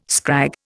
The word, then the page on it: scrag: Indian